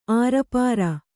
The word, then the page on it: ಆರುಪಾರ: ārupāra; dial.